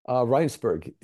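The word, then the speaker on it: Rijnsburg: Did he moved to Regensburg, right? – Rheinsberg, yes. Yeah, it's the Dutch pronunciation.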